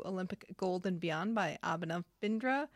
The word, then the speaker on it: Abhinav Bindra: It's Abhinav Bindra.